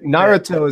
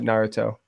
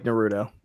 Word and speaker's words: Naruto: Naruto is how it's pronounced in Japan. I watched it in Japanese, so I pronounce it Naruto. – Okay. I was saying like Naruto. – I think that's how it's said in America.